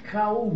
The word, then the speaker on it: کوی: ساخته جدیدن اوستاشناسان میگن این کوی تلفظ درستی نیست کاو می نیستن کاو یعنی واو رو نمیخونن